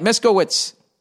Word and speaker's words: Miskiewicz: Oh, I said it right, Miskiewicz. Phonetic spelling right next to it.